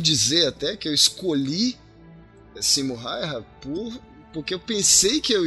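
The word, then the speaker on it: Simo Häyhä: Eu queria falar aqui então sobre Simo Raiha. Dario, minha pronúncia do finlandês foi adequada? Foi? – Sim. – O A com trema no novo acordo ortográfico finlandês pronuncia. Correto.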